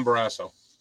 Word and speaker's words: Barrasso: I don't know if this guy's really an asshole, but his name rhymes with it. Tom Barrasso.